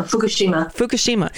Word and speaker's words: Fukushima: Do you pronounce it Fukushima or Fukushima? – No, Fukushima.